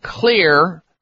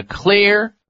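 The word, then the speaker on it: Xlear: This is a nasal spray that contains not only xylitol, and that's why Clear is spelled with an X, X-L-E-A-R. The X is for xylitol.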